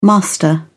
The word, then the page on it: master: British English